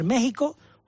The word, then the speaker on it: México: Vale, porque la X que escribe, con la que se escribe México y Texas, la X con la que se escribe, se pronuncia J, que es la grafía española del siglo XVI, que era con valor gutural, o sea, de J. Por lo tanto, nosotros debemos escribir con X y pronunciar México, Oaxaca y Texas.